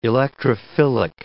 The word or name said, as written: electrophilic